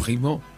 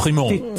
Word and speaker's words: Primaud: Y este corto, bueno, es francés y está dirigido por Vincent, vamos, perdonen por la pronunciación, Vincent Primaud y Heidi... – Primo. – Sí, sí. Vincent Primaud.